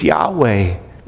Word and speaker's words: Yahweh: In fact, the vowel pointing in the Hebrew says it's Yahweh, right? That's how you say his name.